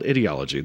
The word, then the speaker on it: ideology: It's called ideology. The reason it's called ideology is because it rhymes with idiot,